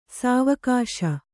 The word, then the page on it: ಸಾವಗಿಸು: Kannada; sāvagisu